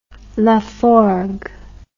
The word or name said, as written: Laforgue